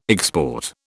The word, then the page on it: export: British English